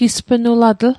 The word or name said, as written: gispnulatl